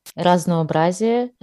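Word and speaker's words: Raznoobrazie (Разнообразие): Also auf Russisch wird es Raznoobrazie, heißt Vielfalt, ausgesprochen.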